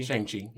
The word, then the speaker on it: Shang-Chi: Am I saying it right? Shang-Chi? – Shang-Chi. – Yeah. Shang-Chi, okay.